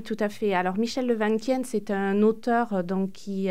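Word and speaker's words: Le Van Quyen: Vous avez évoqué le chercheur en neurosciences à l'INSERM, là, Michel Levanquien, c'est comme ça que vous le prononcez. – Ça, oui.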